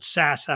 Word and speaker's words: SAS: Chris is going to talk to us about a new partnership they announced this week with a big data provider called SAS, or called SAS, actually, is the way you pronounce it.